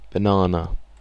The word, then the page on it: banana: British English